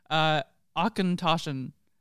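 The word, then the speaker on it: Auchentoshan: So this is, and the reason why we knew sort of how to say it is because on the box they give a nice pronunciation, Achentoschen, which just sounds German.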